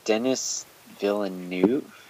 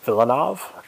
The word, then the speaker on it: Villeneuve: Is it Denis Villeneuve? – Villeneuve? Close.